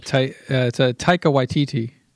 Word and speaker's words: Taika Waititi: Oh yeah, it's a Taika Waititi.